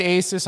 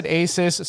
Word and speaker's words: Asus: So how do you pronounce that brand? I just said Asus. Some people say Asus. Not Isis. Asus. – Yeah. – Yeah. – I would say it like you. I think it's Asus. Asus is weird. It doesn't. There's not a little accent over the U. I mean, that would be Asus if it had an accent.